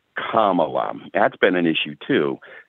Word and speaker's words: Kamala: just say Pamela with a K. It's Kamala.